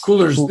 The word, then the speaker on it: Coolors: Coolors. It's spelled Coolers. Cooler. Sorry. Yes. – Coolors. No, you said it.